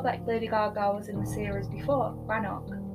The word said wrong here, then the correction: Roanoke: Yeah, she was like the witchy demon. – Yeah. – Roanoke. – Roanoke, there we go. You always say it wrong.